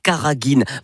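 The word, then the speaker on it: carraghène: Ça s'écrit C-A-2-R-A-G-H-2-E-N, mais ça se prononce bien Karagine.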